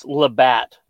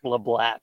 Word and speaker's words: Labatt: It's Labatt, not Leblat.